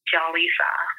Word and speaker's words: Jellisa: It's spelled Jaleesa, J-E-L-L-I-S-A. Not the Dominican way, which is Jaleesa.